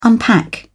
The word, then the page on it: unpack: British English